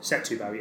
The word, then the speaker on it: Setúbal: Moscatel de Stubal. – De Stubal. – Stubal. – phonetically, that's basically spelled Setúbal. – Setúbal, yeah. – Moscatel de Setúbal.